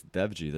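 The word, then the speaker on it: Dewji: It's Devji. The W is like a V.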